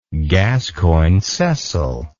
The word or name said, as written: gascoyne cecil